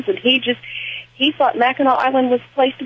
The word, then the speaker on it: Mackinac: Yeah, and it, it, people pronounce it Mackinac too, but Mackinac is really what Mackinac. it's called. Mackinac.